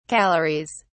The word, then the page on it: calories: British English